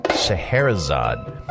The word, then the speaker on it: Scheherazade: Scheherazade.